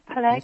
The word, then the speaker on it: Peleg: Okay, Genesis 10:5 records, I think it's pronounced Peleg, the great-great-grandson, yes, Peleg, okay.